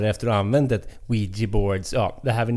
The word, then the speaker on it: Ouija: Den säger Ouija. – Ouija. – Ja, Ouija.